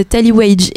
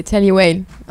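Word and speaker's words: Tally Weijl: Et, du coup, j'ai une question parce que moi je dis euh, Tallywage. Et quelle est la vraie euh, prononciation de ce magasin C'est Tallywale.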